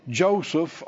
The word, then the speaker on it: Yosef: Joseph, or that's how we pronounce it. It'd be Yosef, I think is how it is, maybe more correctly.